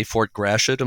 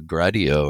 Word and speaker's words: Fort Gratiot: When I say Fort Gratiot, am I saying it more or less correctly? – That is absolutely correct. Yep. – Okay. And I took 3 years of high school French. So my inclination is to think it's a Gradio or something along those lines, more of a French pronunciation.